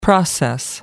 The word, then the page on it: process: American English